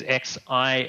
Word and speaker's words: Xi'an: Yeah, Xi is west and on is like safety. Okay. So yeah, it's Xi'an is west safe place.